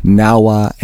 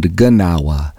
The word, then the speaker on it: Gnawa: Hassan Hakmun specializes in a tradition that is pronounced both Nawa and Ganawa. It is spelled G-N-A-W-A.